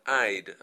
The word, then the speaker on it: I'd: Ahora, vimos ahí una contracción, que es I más would, que se pronuncia I'd. A ver, voz femenina. – I'd. – Okay, now, it is important that you pronounce this correctly, because if you say I like, and you wanted to say I'd like, it's totally different.